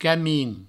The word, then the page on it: camin: provençal